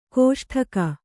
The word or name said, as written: ಕಸೊಡಿಗೆ